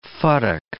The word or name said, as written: futtock